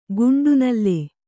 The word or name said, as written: ಕಂಠನಾಳ